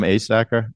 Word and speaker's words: Asacker: Well, if they Google my name, Tom Asacker, the last name is spelled Asacker.